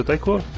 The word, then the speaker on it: taiko: Nossa, o jogo em taiko, se botar isso no carrão, o pessoal... – É isso, taiko.